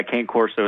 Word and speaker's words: Cane Corso: Well, there's different pronunciations, but, yeah, Cane Corso is how we always pronounce it.